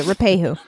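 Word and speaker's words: Ruapehu: Lahars are a recurring natural event associated with Mount Rapahu. I'm saying that wrong. Rapahu? – Megan Rapinoe. – No, you're saying it right. – Okay, Rapahu.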